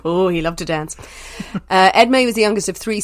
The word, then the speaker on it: Edmé: I think I'm saying that right, Edmé. It's E-D-M-E, French name Edmé. As long as I don't call him Esme, then it's fine.